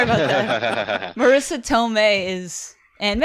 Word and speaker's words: Tomei: Is it Tomei or Tormé? Tomei.